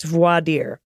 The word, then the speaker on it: voir dire: The questioning of potential jurors to see if they have bias in a case is a French term, voir dire. It's pronounced voir dire.